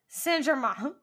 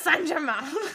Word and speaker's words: Saint-Germain: And there's like a French way to say that one, but I'm not going to try because I'm not going to sit here and embarrass myself with pronunciations in different languages. – I will. Saint-Germain. Paris Saint-Germain. Germain.